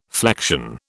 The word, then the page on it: flexion: British English